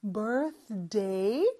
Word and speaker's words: birthday: 그리고 birthday의 th 발음에서 뒤로 넘어가는 거 birthday 그거 많이 연습해 주시고요.